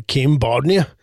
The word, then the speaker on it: Kim Bodnia: Ja, men det var bara för att jag såg det och blev faktiskt genuint glad, för jag tycker då att Kim Bodnia, ja. Är det så man uttalar det? Kim Bodnia? – Ja, ett faktiskt.